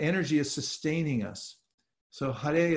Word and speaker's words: Hare: And it's first it starts with Hare, and that's how you pronounce that, H-A-R-E.